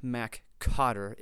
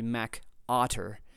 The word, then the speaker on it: MacOtter: which is not MacCotter. It's, even though that's kind of how it's spelled sometimes, it's actually MacOtter.